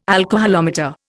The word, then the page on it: alcoholometer: Indian